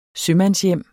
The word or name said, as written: sømandshjem